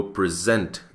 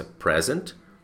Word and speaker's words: present: Então, se eu disser para você, eu vou apresentar a solução para vocês, I will present the solution to you. Então, a mesma palavra pode ter a pronúncia present e present.